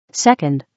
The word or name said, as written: 2nd